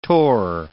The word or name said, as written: torr